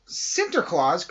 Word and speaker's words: Sinterklaas: Sinterklaas comes to New York. Now, you might think I said that wrong, but I didn't.